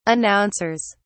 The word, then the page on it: announcers: British English